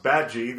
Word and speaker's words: Badgie: And in this one, as the trailer shows you, they meet Ricky Gervais, who's Dominic Bad Guy, even though he says it's pronounced Badgie, that it's French.